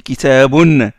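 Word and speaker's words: كتابٌ: puisqu'on dit kitabun. C'est vrai qu'on prononce le un à la fin, mais c'est pas marqué sur la graphie. Kitabun, on la prononce, mais ça s'écrit pas.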